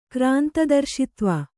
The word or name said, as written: ಕ್ರಾಂತದರ್ಶಿತ್ವ